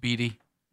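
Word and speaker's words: Beede: Is Tyler's name pronounced Beattie, Dano? – Yep.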